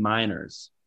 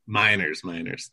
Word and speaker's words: Meinerz: named Quinn Meniers. I don't know if I'm saying that right. – Yeah, I think it's Quinn Meinerz. – Meinerz. That makes more sense.